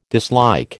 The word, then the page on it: dislike: American English